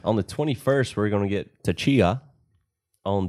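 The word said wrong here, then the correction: Tchia: Is that how you say it? – Oh, yeah. I mean, I think it's Chia. – Chia? – Yeah. – Okay, Chia. – T is silent.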